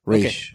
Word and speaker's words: Ra's al Ghul: Raish. – Okay. – That's how they pronounce it in Arrow.